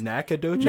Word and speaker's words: Nacogdoches: Nacogdoches? – Nacogdoches.